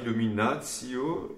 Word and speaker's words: illuminatio: Dominus illuminatio mea et salus mea quae ti Petit détail, illuminatio, l'accent tonique est sur le illuminatio, qui n'a qu'une note, encore faut-il marquer un petit peu cette syllabe.